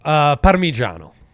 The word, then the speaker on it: Parmigiano: Parmigiano.